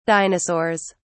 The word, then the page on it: dinosaurs: British English